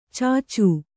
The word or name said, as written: ಚಚು